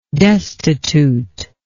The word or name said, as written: destituteness